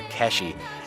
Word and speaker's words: K.VSH: Now, we've got an artist that spells it K.VSH. It's actually what you would say, the way you would pronounce it is Cash or Keshi.